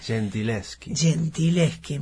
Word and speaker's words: Gentileschi: Si hoy conocemos más Artemisia Gentileschi o Gentileschi, no sé cómo se pronuncia exactamente. – Gentileschi, me imaginé.